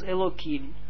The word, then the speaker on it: Elohim: Elohim, dat wordt uitgesproken ook als Elohim.